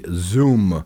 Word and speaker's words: Xoom: It's the Zoom. – Zoom. XOOM. You end up pronouncing it like Inspector Clouseau.